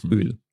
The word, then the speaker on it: Öl: Yes, I'm also maybe slightly exaggerating it when I pronounce the long one. So it's like Öl, Olivenöl.